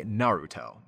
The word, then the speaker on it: Naruto: Like Naruto. A lot of Americans say Naruto, but you know, Yuri Lowenthal, who also speaks Japanese, always says Naruto, and he's got that tapped R in there.